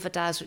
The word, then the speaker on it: Vadasz: The company is called Vidaz, which is spelled V-A-D-A-S-Z.